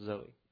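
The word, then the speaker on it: Zoe (ζωή): And this is the spiritual life, which, by the way, is pronounced Zoe.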